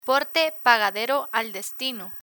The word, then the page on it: porte pagadero al destino: spanish